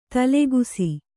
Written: ದಾಲಕ್ಷ

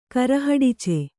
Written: ಕರಹಡಿಚ

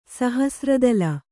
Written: ಸಹಸ್ರದಳ

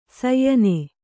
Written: ಶ್ಯಾಣ

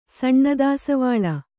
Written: ಸಣ್ಣದಾಸವಾಳ

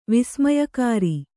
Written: ವಿಸ್ಮಯಕಾರಿ

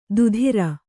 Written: ದುಧಿರ

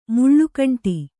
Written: ಮುಳ್ಳುಕಂಟಿ

